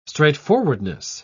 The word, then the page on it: straightforwardness: British English